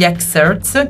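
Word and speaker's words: Xcerts: Uno è il concerto degli Xerts, che si scrive X-Certs nel caso lo dovesse cercare, giovedì 21 marzo in Santeria Toscana 31.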